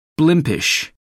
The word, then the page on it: blimpish: American English